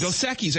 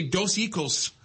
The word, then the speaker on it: Dos Equis: I never say this beer right, the Mexican beer. Dos Equis? – Dos Equis. I can never say it right. I always say Dos Equis, but it's Dos Equis.